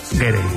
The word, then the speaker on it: Gere: pensando yo que si te apellidas Ger, bueno, ¿cómo se dice Ger o Gerre? – Gerre. Me ha hecho gracia porque has dicho Richard Ger y luego el hijo era Gere. – Yo creo que es Richard Gere.